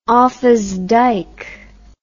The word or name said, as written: offas dyke